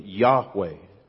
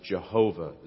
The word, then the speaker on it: Yahweh: You may remember that when you see in the Bible the word Lord in all capitals, it is signifying God's proper name, Yahweh. Years ago, they used to pronounce it Jehovah. It's the same word.